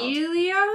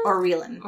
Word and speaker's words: Aurelan: It was spelled A-U-R-E-L-A-N, but it was pronounced Aurelin.